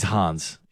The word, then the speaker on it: Hans: He's Hans. He's German. – I was trying to rhyme with fan.